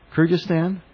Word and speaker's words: Kyrgyzstan: And then in May, you were sent to Kyrgyzstan? – Yes.